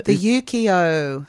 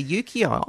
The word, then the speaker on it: Ukiyo: the Ukiyo. – Yes, the Ukiyo.